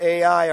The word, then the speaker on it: Ai: That city is spelled Ai. You could call it Ai or I. I just call it I because we're talking about the battle of self.